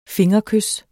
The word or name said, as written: fingerkys